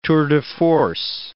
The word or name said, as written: tours de force